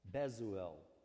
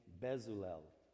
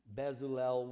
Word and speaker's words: Bezalel: who he is this character called Bezuel, okay? and that's the best I can pronounce his name, Bezalel.